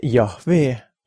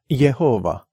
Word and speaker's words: Jahvee: Kui Vanas Testamendis esineb suure algustähega sõna issand, siis on algtekstis tähed J-H-V-H, kui neid nimetada eestikeelse häälduse järgi. See on Jumala nimi, mida hääldatakse Jahvee või nagu see eestikeelsetes piiblites varem on olnud Jehova.